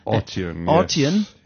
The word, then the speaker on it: Arteon: Arteon. – And the name Arteon, if I can, the emphasis is put on the first syllable, is derived from two I can't even say this.